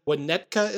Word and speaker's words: Winnetka: Shooting took place in Winnetka, Illinois. Am I saying that right, Illinois guy? – Yes, you are saying that.